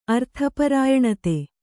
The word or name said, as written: ಅರ್ಥಪರಾಯಣತೆ